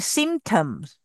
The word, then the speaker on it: symptom: So now, can everyone say symptoms? – Symptom. – Symptom 这个 P 是不用发音的。OK，symptom.